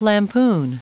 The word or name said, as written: lampoon